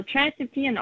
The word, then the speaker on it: trans-Neptunian: And am I even saying that right? – Yeah, you're saying it right. So trans-Neptunian objects, sometimes we call them TNOs, like their abbreviation.